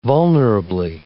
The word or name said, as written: vulnerably